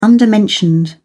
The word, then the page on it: undermentioned: British English